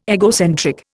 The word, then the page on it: egocentric: Indian